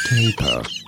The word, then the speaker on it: tapir: In British English, it is said as taper. Taper. In American English, however, it is usually pronounced as taper, taper, or taper.